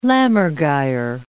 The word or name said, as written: lammergeyer